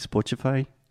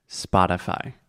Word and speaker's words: Spotify: First, the first vowel sound is ah, spa. Just like I'm going to the spa to get a massage. – Spa. – Spotify.